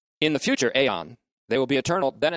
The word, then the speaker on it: aion: That is, their life in the future aeon, A-I-O-N, long O, which will be eternal.